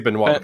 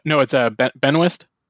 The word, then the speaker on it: Benoist: How do you pronounce that? I always say Benoit because it's the French Canadian way. – Benoist. – Benoist? – Yeah, I think so.